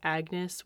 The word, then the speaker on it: Agnes: Anne might have gone by Agnes, which, as far as I can tell from what I've read, Agnes would have been pronounced kind of without the G or a soft G at the time, so more pronounced Agnes.